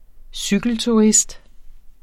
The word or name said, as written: cykelturist